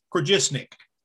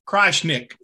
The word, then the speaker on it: Krajisnik: The, the J is pronounced as a Y, so it's Krajisnik. – Krajisnik.